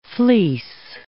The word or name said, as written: fleece